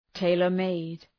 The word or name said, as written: tailor-made